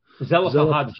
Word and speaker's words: Zelophehad: Am I saying that right? Zelophehad's daughters? Uh, daughter. – Zelophehad's daughters. Thank you very much.